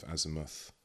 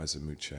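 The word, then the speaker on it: Azymuth: So another of my early releases around then was a remix of Azimuth, or Azimuche, I should say, is the correct pronunciation, with the Peanut Butter Wolf remix on the flip.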